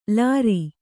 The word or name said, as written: ಲಾರಿ